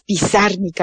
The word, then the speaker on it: Pizarnik: Yo siempre le pongo el acento en la última I, pero es Pizarnik, Alejandra Pizarnik.